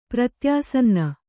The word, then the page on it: ಪ್ರತ್ಯಾಸನ್ನ: pratyāsanna